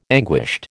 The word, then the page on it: anguished: American English